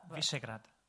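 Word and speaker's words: Višegrad: Visegrad.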